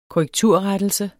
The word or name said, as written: korrekturrettelse